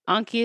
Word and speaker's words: Anki: Anki is spelled A N K I, correct? Yeah. Anki.